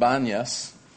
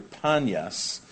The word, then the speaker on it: Banias: And they're there in an area that today is called Banias. It's because the Arabs can't pronounce P, they pronounce B, so it's really Panyas, as in the worship of the god Pan.